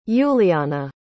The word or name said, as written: Yuliana